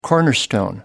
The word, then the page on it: cornerstone: American English